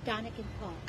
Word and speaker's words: Dunnigan: Right now, you're looking at, and how's it pronounced? Dunnigan? – Dunnigan Park.